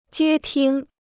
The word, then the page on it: 接听: jiē tīng